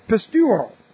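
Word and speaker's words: pisteuo: The word is pistuo. Here's our word. And this is the word that we're talking about. P-I-S-T-E-U, long O. Pistuo.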